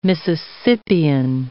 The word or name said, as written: Mississippian